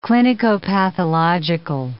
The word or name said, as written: clinicopathological